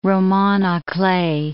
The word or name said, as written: roman a clef